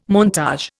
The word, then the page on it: montage: Indian